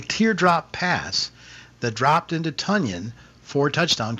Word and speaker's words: Tonyan: That touchdown pass from Aaron Rodgers to Robert Tonyan, and by the way, that's how he's pronouncing his last name now. Tonyan. – Correct. – Tonyan. Did you guys see that? Correct. Yeah. – I did. – Think of Funyan, yeah. Bobby Tonyan, Robert Tonyan.